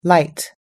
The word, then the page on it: light: American English